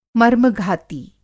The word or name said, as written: मर्मघाती